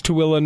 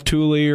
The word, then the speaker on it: Tooele: Sure, but in Canada, just like it's Tooele, not Thule, or, you know, any of those things here, it's pronounced Regina.